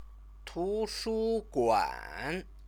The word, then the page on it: tushuguan: Chinese